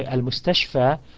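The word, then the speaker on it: al-mustashfa (المستشفى): So the word is al-mustashfa and it finishes with a alif maqsura, meaning it finishes with a long vowel of fatha, long fatha vowel sound of a, and it does not take the kasra.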